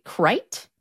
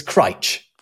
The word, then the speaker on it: Crich: C-R-I-C-H. It looks like Critch, but it's... – I would say Crite? – Not far off. It's Critch. – Critch, okay. – Yeah, yeah. If you pronounce it any other way, you ain't from round here.